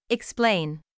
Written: explain

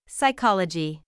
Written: psychology

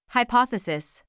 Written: hypothesis

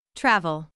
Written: travel

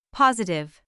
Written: positive

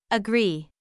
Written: agree

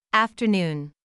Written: afternoon

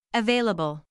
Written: available

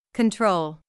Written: control

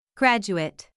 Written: graduate

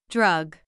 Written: drug

